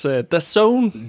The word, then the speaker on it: DAZN: Du får DAZN, som udtales DAZN. – DAZN.